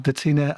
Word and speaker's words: Detina: And we have Detina and Argita.